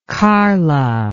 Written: karla

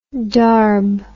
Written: darb

